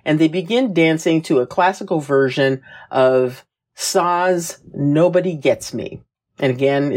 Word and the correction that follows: SZA: That's why you pronounce SZA wrong. – Yeah. SZA, yeah, okay.